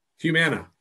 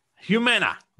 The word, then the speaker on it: Humana: Humana. Humana.